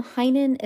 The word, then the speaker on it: Heynen: Heinen is spelled H-E-Y-N-E-N.